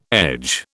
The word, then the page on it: edge: British English